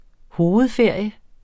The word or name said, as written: hovedferie